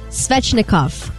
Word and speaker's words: Svechnikov: Andrei Svechnikov.